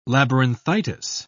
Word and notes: labyrinthitis: British English

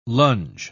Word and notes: longe: British English

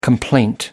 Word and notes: complaint: American English

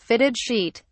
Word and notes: fitted sheet: British English

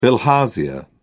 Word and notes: bilharzia: British English

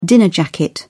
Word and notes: dinner jacket: British English

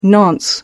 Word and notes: nonce: American English

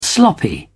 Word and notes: sloppy: British English

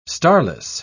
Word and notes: starless: British English

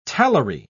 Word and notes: talari: British English

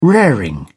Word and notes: raring: British English

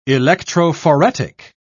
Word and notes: electrophoretic: British English

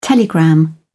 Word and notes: telegram: British English

